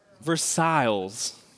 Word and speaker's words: Versailles: It's not like Sedal, you know, Versailles, right? Come on, Missouri. We can do better, all right? Versailles. There we go.